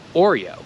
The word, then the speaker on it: Oreo: Bo na przykład jak masz Oreo, to pierwsze O to jest takie O, or, or, yeah, Oreo. Ale ostatnie O to jest O, Oreo, Oreo.